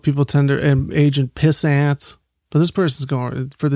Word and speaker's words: Pissant: I remember in that movie he goes, "Oh, it's spelled Pissant. It's pronounced Pissant. It's French. It's French." – Pissant. – Yeah. So Like croissant, but with piss.